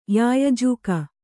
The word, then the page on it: ಯಾಯಜೂಕ: yāyajūka